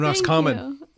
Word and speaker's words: Roscommon: And Roscommon. – Yeah. – Roscommon is not easy to pronounce. – Yeah. – Well done.